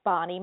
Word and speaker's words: Bonnie: Also, you can reach me at Facebook, Bonnie Mosenter Albers, so I'll spell it for you, B-O-N-N-I-E, and then my maiden name was Mosenter, M-O-Z-E-N-T-E-R, Albers, A-L-B-E-R-S.